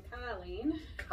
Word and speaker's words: Colleen: I am from Chicago, so I pronounce my name Colleen.